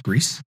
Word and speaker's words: Gris: Actually, I never played Gris. – Strongly recommend it, Chris. – Gree, I guess it may be, depending on. – I do believe it's pronounced Greece, which is, I think it's French for gray.